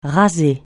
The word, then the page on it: raser: francais